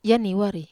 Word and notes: aniwar: Wamesa

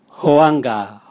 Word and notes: hoanga: Raga

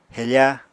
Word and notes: hefa: apatani

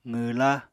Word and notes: qwwla: apatani

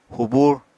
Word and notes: hubur: apatani

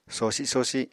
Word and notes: sosi-sosi: Apatani

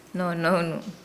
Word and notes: nounu: Nukuoro

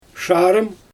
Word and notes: ʃarym: Xyzyl

